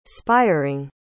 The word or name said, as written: spiering